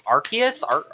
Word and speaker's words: Arceus: Arceus.